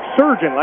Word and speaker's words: Srdjan: His name spelled S-R-D-J-A-N, pronounced like surgeon, like a doctor.